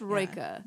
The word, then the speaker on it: Rilke: Okay, so then I don't know how to say it because I knew I was going to say his name wrong. His name, I know, right? It's Rilke.